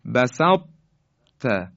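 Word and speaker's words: بسطت: ما دیگه نمیتونیم بگیم بسط ت یعنی ت رو مشدد کنیم، اما به این شکل باید بگیم بسط ت، بسط ت، یعنی اون درشتی تا صفتش یه مقداری اثرش باقی میمونه.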